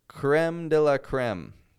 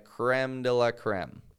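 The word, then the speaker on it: crème de la crème: That's how Americans say it. And I know any French listeners that I have are grinding their teeth and clenching their fists hearing me say it like that, creme de la creme. It's the creme de la creme. That's what we Americans say. Sorry. It's not as fancy as the French way, but it's what we do.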